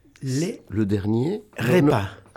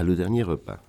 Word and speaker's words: Le dernier repas: El tema en castellano es La última cena, pero yo le voy a pedir que lo pronuncie él en francés, que tiene una elegancia bastante mayor que la mía a esta hora. – Le dernier repas. – Le dernier repas. – Le dernier repas.